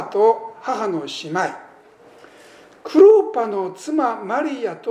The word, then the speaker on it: クロパ: クレオパというのは、ユダヤ読みにいたしますとクロパというふうになるんだそうでありまして、多分夫婦かな、そんなふうに思うわけですね。